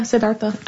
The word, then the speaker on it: Siddhartha: Yeah, Siddhartha.